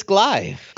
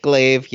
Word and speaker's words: Glaive: but we're going to get Loki and Corvus Glaive. Glaive, yes, I said it wrong.